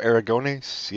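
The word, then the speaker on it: Aragonés: Sierra Sergio Arago. I don't know how to say his name. Sergio Aragones.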